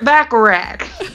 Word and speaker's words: Bacharach: Burt Bacharach. – What? Is that... Did I mispronounce his name? Burt Bacharach? Burt Bacharach. – No, that's right.